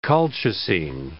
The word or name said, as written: colchicine